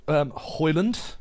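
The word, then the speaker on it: Højlund: Nice to see Hoyland on the score sheet. And Rashford. – I'm more glad that you've got his name right this time.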